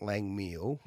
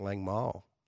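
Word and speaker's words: Langmeil: Now, Kaz from Kilmore said it's not Lang Meal. – No. – The Grenache. I think it's pronounced Lang Mile.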